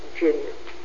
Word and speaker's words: Dzienis: My maiden name was Helen Janus. Janus means day in Polish. I'm Polish. – How do you spell that? – D-Z-I-E-N-I-S. Janus. The D-Z is the G sound.